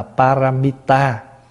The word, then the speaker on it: paramita: Cái chữ ba la mật này là tiếng Phạn, và chúng ta phiên phát âm từ cái chữ Phạn là paramita. Và paramita thì chúng ta âm lại là ba la mật đa, và khi có khi gọi tắt là ba la mật.